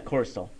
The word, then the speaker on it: Corey Stoll: We had Corey Stoll. I don't know how you say it. – Yeah, Corey Stoll.